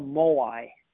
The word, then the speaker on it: moai: In Okinawa, they call this a moai. It's spelled M-O-A-I, moai.